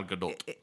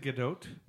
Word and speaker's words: Gadot: But Gal Gadot. – Yep. – You actually pronounce the T. – Is it Gadot? – Yeah.